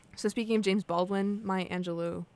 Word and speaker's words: Maya Angelou: So I have the exact pronunciation. – Okay. – Oh, hold on. – Maya Angelou. – Maya Angelou.